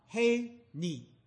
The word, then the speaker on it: Hineni: 他特别跟我讲 CH 念 H 啊 H，写了 Hineni Hineni 写了 Hineni，请差遣我。